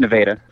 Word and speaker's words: Nevada: And the one thing that's interesting, so you're actually also mayor of Nevada, Iowa, however you say it. I'm not sure of the exact pronunciation. So it's Nevada. – It's Nevada. Okay.